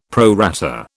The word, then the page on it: pro rata: British English